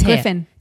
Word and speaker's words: Griffin: Griffin.